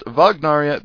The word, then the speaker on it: Wagnaria: Anyways, it's basically a family restaurant, named after the famous composer Wagner, and so it should be pronounced Vagnaria because he was German and the W is pronounced as a V in German.